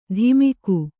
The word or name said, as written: ಧಿಮಿಕು